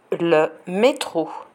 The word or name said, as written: le metro